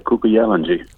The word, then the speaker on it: Kuku Yalanji: Yeah, now you've just released a track, a very, very beautiful track, Kuku Yalanji. Did I say it correctly? – Yeah, Kuku Yalanji. – Kuku Yalanji.